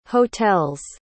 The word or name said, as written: hotel's